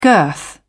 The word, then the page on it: girth: British English